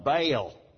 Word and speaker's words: Baal: In the Canaanite religion, the male deity was Baal, B-A-A-L, pronounced in good southern drawl when I was in Sunday school, Baal. They were the worshipers of Baal. Well, that's a double vowel. It's Baal.